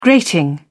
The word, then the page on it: grating: British English